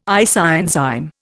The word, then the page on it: isoenzyme: Indian